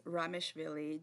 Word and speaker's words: Ramishvili: It's Ramishvili, just as it's written.